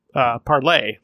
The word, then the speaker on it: Parler: And then Parler, which I think should be pronounced Parlay, like France, French, but maybe not.